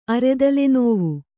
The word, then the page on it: ಅರೆತಲೆನೋವು: aretalenōvu